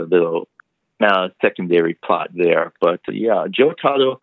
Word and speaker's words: Jotaro: Anyways, a couple more characters I just wanted to discuss is one character that I thought was interesting, that is the character, I'm going to probably pronounce it wrong, Jotaro. Am I anywhere Jotaro. – Close? – Yeah, that's Jotaro. Yeah, that's Usagi's son.